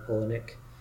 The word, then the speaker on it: Palahniuk: So, you know how in like Chuck Palahniuk, that's how you pronounce it by the way, Palahniuk,